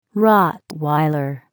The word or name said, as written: Rottweiler